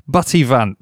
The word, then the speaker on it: Buttivant: Now, can you spell it for me, please? – Yes, of course. A B U with the double T I V A N T. – Buttivant. – Buttivant, as it sounds.